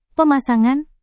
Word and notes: pemasangan: Indonesian

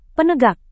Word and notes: penegak: Indonesian

